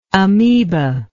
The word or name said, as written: amebalike